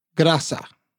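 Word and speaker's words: Graça: It's Graca.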